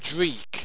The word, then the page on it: dreek: Owstwecian